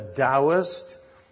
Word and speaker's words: Taoist: Well, for one, the Taoist, T-A-O, pronounced D-O-W, the Taoist, the Zen Buddhist, who also believe in the Tao.